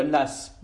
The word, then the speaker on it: Law: La France est en retard notamment en raison de l'échec de John Law, euh, prononcé Las, d'ailleurs. Ça s'écrit Law L-A-W et ça se dit John Las.